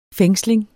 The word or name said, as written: fængsling